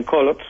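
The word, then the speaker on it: Coloured: Digamos colored. Leído, sí. Ellos lo pronuncian Colots, son pues descendientes de malayos, de migrantes malayos, generalmente trabajadores de ferrocarril.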